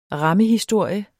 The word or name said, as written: rammehistorie